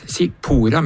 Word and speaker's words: Sippora: Og da fant vi at Sipora finnes som navn. – Sipora. – S-I-P-P-O-R-A, og det uttales Sipora med trykk på por.